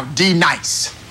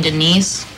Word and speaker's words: D-Nice: Now, D Nice. – Do you mean Denise? – Son of a... You say your name right now. – Denise. – Say it right. – Denise. – Correctly. – Denise. – Right. – Denise. – Right. – D-Nice. – That's better.